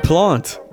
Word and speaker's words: Plante: Plante. – Jacques Plante. – Oh, my God. – Folks, you love it. Goaltender Jacques Plante.